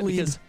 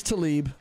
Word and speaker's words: Tlaib: Far-left congresswoman and squad member Rashida Tlaib. – Yes. – How do you pronounce that? – Tlaib. It's Tlaib. – Even though it's spelled T-L-A-I-B. – Yeah, they pronounce it Tlaib.